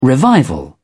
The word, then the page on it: revival: British English